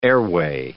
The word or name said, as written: airway